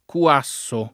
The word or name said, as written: Cuasso